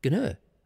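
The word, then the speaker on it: gnurr: Now, have you ever heard of the word, now, I don't even know how you pronounce it. It's G-N-U-R-R, so I don't know if it's got a silent G or a silent N, or you try to say Gnur, Gnur. Maybe it is pronounced Gnur.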